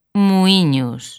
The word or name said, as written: Muíños